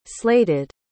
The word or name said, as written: slated